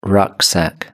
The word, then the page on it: rucksack: British English